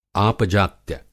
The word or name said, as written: आपजात्य